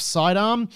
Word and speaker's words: Cydarm: First up, Vaughn Shanks is the chief executive and co-founder of Sidearm, and that's spelled C-Y-D-A-R-M.